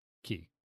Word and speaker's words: Cay: Just any C-A-Ys in general is supposed to be pronounced Key.